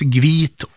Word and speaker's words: hvit: Det uttalas gvit och vass.